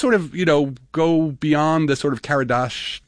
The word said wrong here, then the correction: Kardashev: if I can pronounce it right, the Karadashov. I always get hold on, I've got the Kardashev. – Kardashev, thank you.